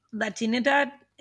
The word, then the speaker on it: Latinidad: Can we just go back a little bit, and can you help us unpack the term Latinidad? Is that, did I say that right? – Yeah.